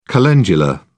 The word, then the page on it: calendula: British English